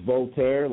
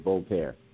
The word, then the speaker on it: Voltaire: It is Voltaire, like the French philosopher. It's spelled V as in Victor, O L, T as in Tom, A I R E, Voltaire.